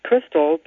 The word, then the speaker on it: Christel: My first name is pronounced Crystal, but it has nothing to do with the chandelier because the spelling is C-H-R-I-S-T-E-L, the German way.